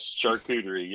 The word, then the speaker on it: charcuterie: By the way, did I say that right? – Yes, charcuterie, yes. and just folks, so you know, you're sitting here laughing, going, okay, they keep talking about charcuterie.